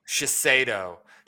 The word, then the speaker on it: Shiseido: Shiseido.